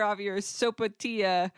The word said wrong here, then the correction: sopapilla: Sopapilla. – I pronounce wow. this word wrong every single time I've said it.